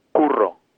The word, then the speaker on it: curro: I'm from Spain originally, so this is going to be a little bit hard unless you can roll your R, which is curro. – Curro. – Can you say that with a double R?